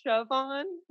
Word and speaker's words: Siobhán: It's Siobhán.